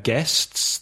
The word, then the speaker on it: guests: So obviously at weddings you have guests, the people who come to the wedding. Again, careful with pronunciation. The spelling G-U-E-S-T-S, but the pronunciation guests.